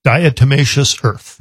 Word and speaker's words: diatomaceous earth: Diatomaceous earth.